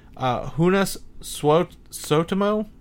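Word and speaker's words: Joonas Suotamo: He's Finnish. His name's pronounced Hunas Sotamo.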